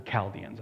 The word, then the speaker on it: Chaldeans: They're known as Chaldeans, that's how it's pronounced.